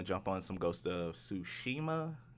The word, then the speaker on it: Tsushima: Yeah, I believe it's Ghost of Tsushima, but so there's no T. That T is silent. Little T is silent. – Yes. Yes. Ah. No T. Tsushima.